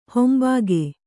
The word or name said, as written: ಹಿಂಭಾಗ